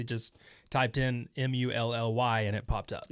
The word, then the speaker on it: Mulley: And it's Mulley. Sometimes it's spelled Mulli, but for the movie itself, it's spelled Mulley.